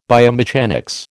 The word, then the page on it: biomechanics: American English